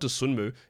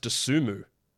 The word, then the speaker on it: Dosunmu: Apparently, according to the Bulls pronunciation guide, it's not Dosunmu, it's pronounced Dosunmu. No, the N silent.